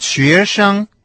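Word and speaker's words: шуэшан (学生): Слово «студент» по-китайски будет «шуэшан». Первый компонент со вторым тоном «шуэ», а второй тона не имеет, то есть произносится с легким тоном.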